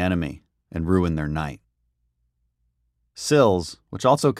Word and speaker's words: SLLS: We call this simple action SLLS, pronounced SILS.